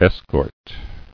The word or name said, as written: Escorting